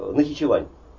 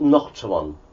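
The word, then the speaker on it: Naxçıvan: И вот среди различных мест, куда мы пропутешествовали, была и Нахичевань. Ну, они это произносят Нахчеван. Нахчеван.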